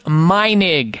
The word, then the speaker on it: Meinig: Root Canal Cover-Up by Dr. George Meinig. He's a doctor of dental surgery, and his last name is spelled M-E-I-N-I-G.